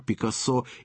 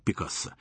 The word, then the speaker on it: Пикассо: Фамилию великого художника произносят и на французский манер «Пикассо», и на испанский «Пикассо», рассказывает Дмитрий Савицкий.